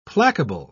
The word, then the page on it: placable: British English